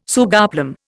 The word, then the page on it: sugarplum: Indian